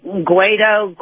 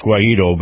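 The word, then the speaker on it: Guaidó: And so we have, I don't know how to really say his name, Juan Guaido, Guaido. – Well, I think it's Guaido, but go ahead.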